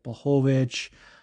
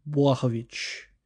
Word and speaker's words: Błachowicz: because we've heard so many iterations, like Błachowicz, but I think it's Błachowicz.